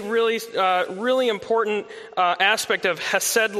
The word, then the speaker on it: chesed: But in order to look super smart and theological, I'll throw a Hebrew term at us this morning, and the term is chesed. That's the only time I'll do the ch, because I don't want to be distracting, but that's how it's pronounced, the chesed love of God.